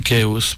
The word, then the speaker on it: Chaos: Ouais, avec le S, Chaos. – Chaos, pardon.